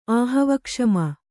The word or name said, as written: ಆಹವಕ್ಷಮ